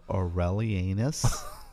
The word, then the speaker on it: Aurelianus: I will try to pronounce it better. Year of the consulship of Aurelianus. Seriously, that's how it's pronounced.